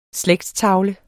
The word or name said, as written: slægtstavle